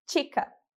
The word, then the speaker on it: chica: O mesmo vale se o CH ele tiver no início da palavra, como em chica, que significa menina. A gente vai pronunciar como chica. Mais uma vez, chica.